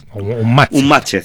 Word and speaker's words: Unmatched: Un matched, sí. Esto escrito literalmente, que es como lo pronuncia todo el mundo, es un matched, ¿vale?